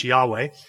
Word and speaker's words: Yahweh: Then the other thing is in the Old Testament, the Tetragrammaton, YHWH, which is the covenant name for God, which we would pronounce Yahweh, they actually translate it as Yahweh in the Old Testament.